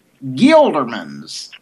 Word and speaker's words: Gildermans: Sean, that's S-H-A-W-N? – That is correct, ma'am. – Can you spell your last name, please? – Gildermans. – Can you spell that, please? – Yes. G-I-L-D-E-R-M-A-N-S.